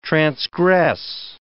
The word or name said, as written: transgress